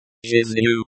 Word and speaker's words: Жизнью: Жизнью, the, ZH, sound is like, ZH, in, treasure. The, N, in. Жизнью is soft, as if you are making the sound, NY.